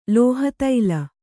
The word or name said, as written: ಲೋಹತೈಲ